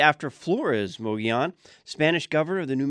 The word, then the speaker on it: Mogollon: The Mogollon Rim, they say, is probably one of the most mispronounced locations in Arizona. I say Mogollon. Locals pronounce it more Mogollon.